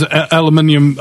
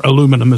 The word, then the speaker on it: aluminium: Like, what do you say to people when you tell them that there's aluminium or aluminum, as the American pronunciation goes,